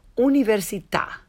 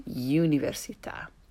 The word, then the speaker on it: università: And università, rather than università. And these are tricky because their English equivalents contain a consonant y sound.